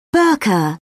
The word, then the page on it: burka: British English